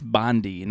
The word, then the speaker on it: Bondi: I think it's pronounced Bondi, not Bondi.